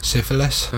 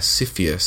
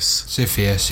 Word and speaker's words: Sisyphus: Syphilis? – No, not syphilis. – Syphilis. – S-I-S-Y-P-H-U-S. Syphius. – Syphius, yeah.